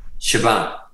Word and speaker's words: Siobhan: Siobhan. – Yep. – Okay. – like Siobhan, like S-H-A-V, as in Victor, yeah. A-N.